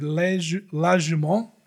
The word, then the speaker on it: La Jument: But La Jument, I think, is how you say it.